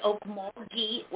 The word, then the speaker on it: Okmulgee: Hi, it's Okmulgee with a very hard G, Okmulgee.